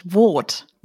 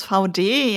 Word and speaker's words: Vaude: Ich muss ja zugeben, ich dachte immer, das spricht man anders aus, nämlich Wot. – Wot klingt irgendwie französisch, aber ich habe es auch erst verstanden, als es mir jemand gesagt hat, wie es wirklich ausgesprochen wird.